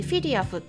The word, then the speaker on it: FEDIAF: FEDIAF と書いてフィリアフって読むんですけれども欧州ペットフード工業会連合と呼ばれる団体があるんですけれども、まあ、それらアフコかフィリアフ